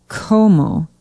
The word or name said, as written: Como